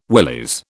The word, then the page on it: willies: British English